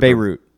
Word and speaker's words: Beirut: I think it's Beirut. – Beirut.